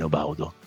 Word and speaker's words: Obaudu: Ah bravo, sei l'unico che lo pronuncia bene Obaudu. Come mai? – Perché sennò... c'è un'intervista che lui dice che si pronuncia così, Obaudu.